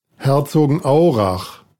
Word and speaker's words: Herzogenaurach: Yeah, Herzogenaurach. – It's not exactly said that like that. Here, let's play this. – Herzogenaurach. – That's how it's said.